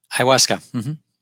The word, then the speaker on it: ayahuasca: I'm not sure if I'm pronouncing this right. Ayahuasca? – Ayahuasca. Mm-hmm.